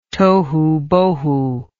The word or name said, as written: tohubohu